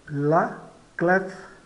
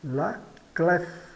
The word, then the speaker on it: la clef: La clef. La clef. A chave. Observe que essa consoante final é pronunciada. De modo geral, as consoantes finais não são pronunciadas, mas o F é pronunciado.